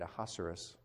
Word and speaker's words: Ahasuerus: Ahasuerus rhymes with rhinoceros.